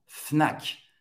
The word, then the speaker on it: Fnac: La Fnac. Todas as letras vão ser pronunciadas la Fnac, hein, para você comprar seus, seus DVDs, seus, suas coisas preferidas eletrônicas, la Fnac, que vende também livros aqui na França.